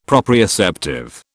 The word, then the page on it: proprioceptive: British English